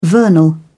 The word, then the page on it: vernal: British English